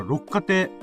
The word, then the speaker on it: 六花亭: 六花亭、六に花と書いて、あの、六花亭。